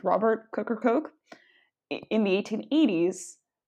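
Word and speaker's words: Koch: I think they are the Coke brothers, but I think I missaid it because of how my stepmother's family pronounces it.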